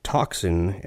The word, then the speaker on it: tocsin: We'll hear his piece called Toxin, and in this case it's spelled T-O-C-S-I-N, as in an alarm bell.